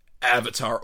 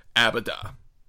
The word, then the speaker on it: Avatar: epic Avatar, or, as Arnold Schwarzenegger pronounced it infamously on the Golden Globes the year that that came out, Abada, which is my favorite mispronunciation.